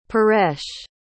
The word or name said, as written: paresh